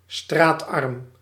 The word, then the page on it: misérable: France; /mi.ze.ʁabl/